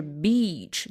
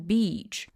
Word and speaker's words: beach: 首先呢，在短音的时候，它就是 bitch，你们会发现没有人会发成 beach，他不会这么发，不会说 beach，他不会咬的那么重，他就是 bitch 带出来。然后如果是 beach 的话，它就是那个沙滩、海滩 beach。所以说呢，发音长短是会非常影响你理解他说的到底是哪个单词的，并且这个概念呢也是拼音里面没有的，这里是需要大家在学习过程中比较注意的一个部分。